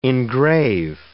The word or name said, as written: engrave